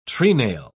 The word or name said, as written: treenail